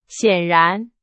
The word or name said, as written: 显然